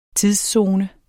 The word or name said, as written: tidszone